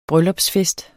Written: bryllupsfest

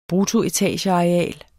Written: bruttoetageareal

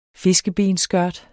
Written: fiskebensskørt

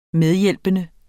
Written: medhjælpende

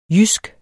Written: jysk

